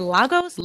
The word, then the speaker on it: logos: do you pronounce this logos? Lo- logos? logos? – Yeah. Logos.